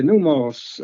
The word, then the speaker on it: pneumos: Okay, pneumos, that's a Greek word for spirit. It's spelled just like pneumonia. It's P-N-E-U-M-O-S, pneumos. Like I said, pneumonia, the first part of that is new means spirit.